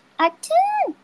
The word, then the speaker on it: atún: Do I remember how to say tuna in Spanish? Atún! That's how you say it. Atún!